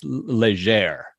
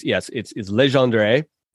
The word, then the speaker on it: Legendre: I grew up hearing it pronounced Légère, like almost the whole end, D-R-E at the end was silent. But it's Legendre.